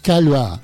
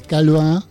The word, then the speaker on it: Caillois: El escritor y traductor Eduardo Berti, tengo el honor de anunciarles que ha sido galardonado con el premio Roger Calva. Para que algunos tengan memoria gráfica, se escribe Caillois, seguramente lo recordarán.